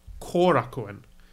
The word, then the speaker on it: Korakuen: Korakuen. Korakuen, as I'm told, is how to pronounce it.